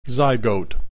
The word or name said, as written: zygote